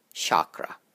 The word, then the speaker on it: chakra: And the CH is pronounced as how you would pronounce the CH in the word chat, C-H-A-T, chat, not how you would pronounce shin or shout. So pay attention to how I pronounce the A's and the C-H. Chakra, chakra, as opposed to chakra. You may have noticed that I rolled my R a little bit, chakra.